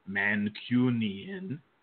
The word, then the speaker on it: Mancunian: I'll just say that again because someone that's going to come on air later is going to say it wrong. It's Mancunian.